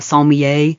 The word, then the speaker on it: sommelier: Think about like wine experts, like a sommelier. Is that how you say it? – Yes.